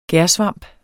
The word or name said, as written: gærsvamp